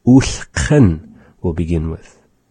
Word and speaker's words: ulhqn: And so that's having our kha, the q. The word is spelled with our nuchalk alphabet. U, sa, kha, na. That is U, L, H, Q, N.